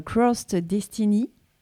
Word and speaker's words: Crossed Destiny: Alors, on parle aujourd'hui de votre groupe de musique, Crossed Destiny. – Oui. – Est-ce que je prononce bien? – Oui, c'est ça.